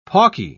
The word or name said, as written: pawky